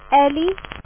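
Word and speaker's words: Ely: Ellie.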